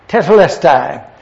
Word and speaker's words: Tetelestai: The word that is used here, and I put it out for you to see, is T-E-T-E-L-E-S-T-A-I. Tetelestai. Over the years, I've had a hard time pronouncing that, but I think I have it correctly now. Tetelestai.